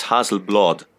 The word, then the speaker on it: Hasselblad: What we basically did, like there was like from Hasselblad, or a Swedish guy told me it's Hasselblad. Hasselblad. That's the pronunciation. Even it's you write an A, but they pronounce it like an O, more or less.